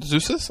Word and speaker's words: Zeus: Ariel Monroe took on Zeus, I think that's how you pronounce it.